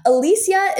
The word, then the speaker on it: Alesia: Alesia is spelled A L E S-I-A.